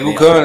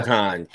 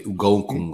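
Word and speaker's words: Eguakun: Egukan, that's how you say it? – Yeah. Egukan. Kingsley Egukan. – I definitely said it wrong in the role. I say Ugokun or some shit. – Yeah, that's very wrong.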